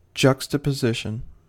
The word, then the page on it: juxtaposition: American English